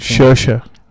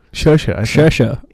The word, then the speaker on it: Saoirse: Saoirse Ronan. – Saoirse? – Saoirse.